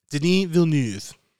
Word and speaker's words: Denis Villeneuve: Can you say his name? – Denis Villeneuve. – The best pronunciation out there.